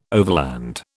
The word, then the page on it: overland: British English